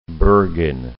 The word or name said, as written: Bergen